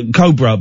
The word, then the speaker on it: Cobra: Anyway, we did a thing for, Cobra beer, but I pronounced it Cobra, and she took me to one side and said, I think it's pronounced Cobra. And I was mortified. But now I say, I really make sure that I say Cobra every single time.